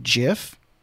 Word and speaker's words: GIF: Now, I know that the creator of the GIF actually pronounces it JIF, but...